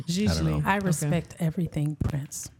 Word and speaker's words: Gigli: It looks like Giggly, but I think it's called, the second G is silent for some reason.